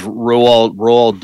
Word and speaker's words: Roald: I think it's Roald Dahl, I think is how you say his name, but I could be wrong.